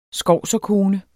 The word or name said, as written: skovserkone